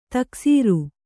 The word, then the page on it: ತಕ್ಸೀರ್: taksīr